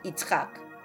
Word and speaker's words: Yitzhak: El nombre de Yitzhak en hebreo contiene tzadi y se pronuncia Yitzhak.